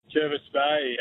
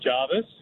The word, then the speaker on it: Jervis Bay: Jervis Bay, as your producer corrected me. I pronounce it Jarvis. He told me the people that grow up there, they called it Jervis.